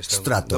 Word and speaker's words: Stratto: Sí, Strato. Doble T final, Corporation.